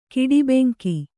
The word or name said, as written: ಕಿಡಿಬೆಂಕಿ